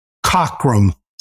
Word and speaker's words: Cockram: Cockrum, C-O-C-K-R-A-M.